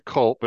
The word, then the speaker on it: culp: I was thinking, you know how C-U-L-P, the C-U-P, maybe the L is silent, so I just want to see it's not silent. It's culp. It's a Latin word that means fault. Culp.